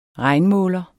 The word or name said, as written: regnmåler